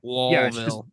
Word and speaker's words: Louisville: You just have to act like there's people in your mouth. – Louisville. – L-O-L-V-I-L-L-E. You got to slur through it.